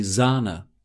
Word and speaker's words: Sahne: Let's note the long vowel sound, die Sahne.